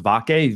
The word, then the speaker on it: Vake: I believe it's pronounced Vake, V-A-K-E.